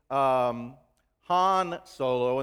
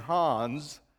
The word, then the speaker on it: Han Solo: Have you noticed, and I've talked with friends, and last time I made the terrible error of mispronouncing Han Solo and saying Hans, and you know, you just kind of lose people and you lose all credibility.